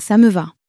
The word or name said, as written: ca me va